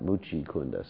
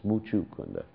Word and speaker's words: Muchukunda: It's not Muchikunda. Sometimes people pronounce this demon's name wrong. They say Muchikunda. It's Muchukunda.